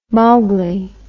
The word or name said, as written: Mowgli